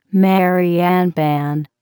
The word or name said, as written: Mary Ann pan